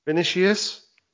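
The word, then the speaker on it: Vinícius: Vinicius.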